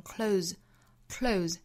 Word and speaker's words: close: 那在这句话里呢，它是动词，我们要把它读成 close，再跟后面的 up 做连读呢，就是 close up。